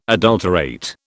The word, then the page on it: adulterate: British English